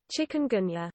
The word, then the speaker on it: chikungunya: How do I say chikungunya pronunciation? There we go, Google. You know what I want. Oh, here we go. – Chikungunya. – Oh, I wasn't that far off.